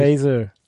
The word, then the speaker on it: Faeser: So spricht man die sonst aus? – Nancy, nee, ist schon richtig. – Faeser.